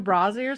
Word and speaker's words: Brasi: Luca Brasi? – Is that the way you say it? – Is that how you say it? – No. – Brasi. – Luca Brasi or something? – Luca Brasi.